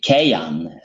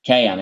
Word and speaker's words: Kayin: So, and after he brought forth is Kayan. So it's different. It is not spelled like Cain. It's Kayan. It's a different writing. – Oh, okay. All right. Well, that's the Hebrew. The either K-A-Y-I-N or C-A-Y-I-N is usually the way that's spelled.